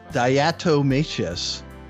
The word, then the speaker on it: diatomaceous: Diatomaceous, earth food grade, 10 pounds. – I don't understand. – Okay, first of all, I actually know how to pronounce that. It's a diatomaceous.